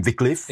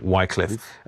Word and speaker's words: Wycliffe: People pronounce him Wycliffe. – Exactly, yeah. And we call him Wycliffe.